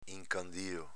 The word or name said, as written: incandio